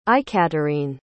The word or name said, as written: aikaterine